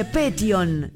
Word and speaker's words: Petión: Yo dije Petión, es Petión, acento en la O.